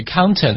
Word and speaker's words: counter: 所以 counter 呢就是柜台的意思我们在发音的时候呢要把这个 out 的音发得夸张一点所以要读成 counter counter yes、 yeah.